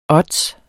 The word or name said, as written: ODDS